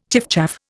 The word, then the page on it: chiffchaff: Indian